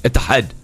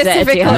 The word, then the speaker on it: Etihad: I think the right way to say it is like how Zainab says. What is it? Etihad.